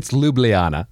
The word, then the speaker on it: Ljubljana: Most people don't pronounce it Ljubljana either. So well, it's Ljubljana. There's an L in there, just FYI. – The L is silent, man.